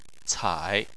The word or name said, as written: cai3